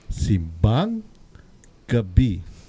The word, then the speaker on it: simbang gabi: the accent is on the second syllable. Okay. if for the second word gabi, you do it on the first syllable. It means a root crop, like a potato. Okay? So you must remember to say simbang gabi.